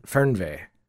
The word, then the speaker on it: Fernweh: F-E-R-N. – Yeah. – W-E-H. – What Fernweh. Language is it? – German. – Fernweh. – I think. – We're gonna say with my very bad German probably. Fernweh.